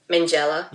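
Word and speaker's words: Minghella: Mangella.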